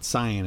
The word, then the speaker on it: cyan: Or I don't know how you pronounce that. Cyan is always what I say. – Cyan, yeah.